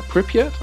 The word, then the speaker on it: Pripyat: Pripyat. I think that's how you pronounce yes, it. Yes, indeed.